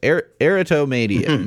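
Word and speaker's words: erotomania: How do you pronounce this? Eritomania? Erito? Yes. Eritomania. Mania.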